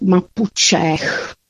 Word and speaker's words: Mapuche: Mapa znamená země a Che, které vyslovujeme s přídechem ch na konci, pak prostě člověk. Tedy, pokud bychom to chtěli vyslovit, tak Mapučech, což je velice zajímavé slovo.